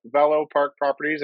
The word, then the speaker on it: Velo Park Properties: So, Hank, you are the owner, designated broker, head honcho at Velo Park Properties. Am I pronouncing that right? – Yes, that's correct. It's if you speak French, Velo is French for bicycle.